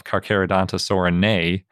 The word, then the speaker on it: Carcharodontosaurinae: It has the most complete skull in the group of Carcharodontosaurinae, or a knee, if you want to pronounce it correctly, which obviously includes Carcharodontosaurus and the closest relatives.